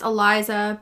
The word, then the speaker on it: ELISA: So in the medical world, it's pronounced ELISA, but it's spelt like Elisa spelt her name.